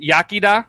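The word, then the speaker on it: iechyd da: Yakida. – Is that how you say it? Yakida? – Yes. It says it's pronounced, it's sometimes anglicized to Yakida.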